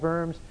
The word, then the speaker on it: Worms: So the Diet of Worms, German, the W, you pronounce it with a V, so the Diet of Worms.